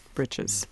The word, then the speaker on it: breeches: It comes from the spelling breeches, B-R-E-E-C-H-E-S, very old word. And it's just often pronounced britches.